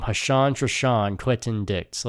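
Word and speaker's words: Ha'Sean: Like, not Dushan, it's Hashan. His name is like Hashan. It rhymes too.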